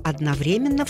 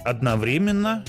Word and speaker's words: одновременно: Русский орфографический, большой орфоэпический, большой толковый и словарь трудности русского языка разрешают два ударения. – Одновременно и одновременно.